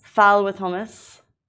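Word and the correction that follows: ful: I've been, my pronunciation of this is all wrong. Fool with hummus.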